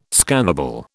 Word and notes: scannable: British English

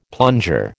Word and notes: plunger: American English

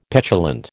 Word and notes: petulant: American English

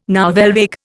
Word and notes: nouvelle vague: Indian

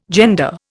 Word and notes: gender: Indian

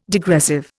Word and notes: degressive: Indian

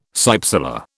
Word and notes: cypsela: British English

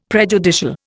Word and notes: prejudicial: Indian

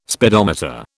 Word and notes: speedometer: British English

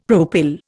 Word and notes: propyl: Indian

